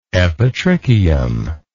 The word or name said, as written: epitrichium